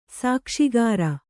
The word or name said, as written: ಸಾಕ್ಷಿಗಾರ